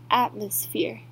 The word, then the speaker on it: atmosphere: Evaporate 就是蒸发的意思，va 发啊的音，e 是发短音，e eva，por 这边是发短音，e 的音，evaporate 是蒸发，那它的名词就是 evaporation，重音在 ra 的地方，evaporation。再我们来看的是 atmosphere 的重音在 at 的地方发啊。的音，mo 是发 us atmosphere，phere 这边是发长音 e atmosphere 是大气的意思。